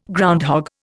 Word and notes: groundhog: Indian

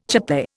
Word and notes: chape: Indian

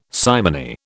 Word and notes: simony: British English

